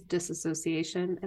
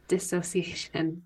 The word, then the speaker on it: dissociation: Everyone says disassociation. And just for the viewers, there's no A. It is dissociation.